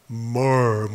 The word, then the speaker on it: myrrh: MYRRH 이렇게 보고서 과연 이걸 어떻게 발음해야 하는가, MYRRH를 어떻게 발음해야 되나, 미르라고 발음해야 되나, 그런 생각을 했는데, 요즘은 뭐 다 좋은 게 있으니까 네이버 들어가가지고 발음을 눌러보니까 more 뭐 이렇게 발음을 하더라고요.